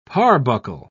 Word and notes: parbuckle: British English

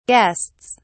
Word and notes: guests: British English

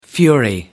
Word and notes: fury: American English

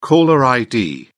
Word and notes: caller id: British English

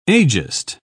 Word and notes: ageist: British English

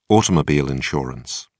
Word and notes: automobile insurance: British English

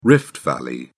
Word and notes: rift valley: British English

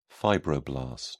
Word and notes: fibroblast: British English

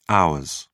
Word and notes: hours: British English

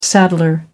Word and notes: saddler: American English

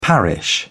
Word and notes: parish: British English

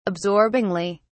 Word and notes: absorbingly: British English

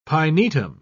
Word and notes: pinetum: British English

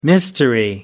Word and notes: mystery: American English

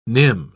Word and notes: nim: British English

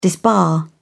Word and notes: disbar: British English

